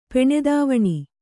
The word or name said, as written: ಪೆಣೆದಾವಣಿ